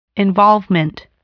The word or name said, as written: involvement